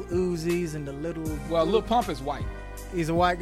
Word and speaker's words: Lil Pump: Yeah, featuring Little Pump. – Yes, Little. Or my bad, Lil Pump, because everything is spelled L I L.